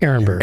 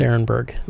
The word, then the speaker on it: Arenberg: Arenberg. – Arenberg. – Well, you see A-R-E, and people think R. It's like, Arenberg. That's how we pronounce it.